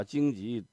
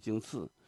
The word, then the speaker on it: 荆棘: 那个玫瑰玫瑰我爱你，把荆棘念成荆刺，到现在还有人念呢。